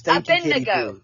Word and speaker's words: Abednego: Shadrach, Meshach, and Abednego. I hope I did that justice. – Abednego.